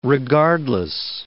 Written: regardless

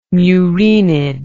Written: muraenid

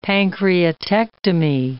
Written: pancreatectomy

